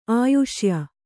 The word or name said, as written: ಆಯುಷ್ಯ